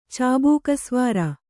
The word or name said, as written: ಚಾಬೂಕಸ್ವಾರ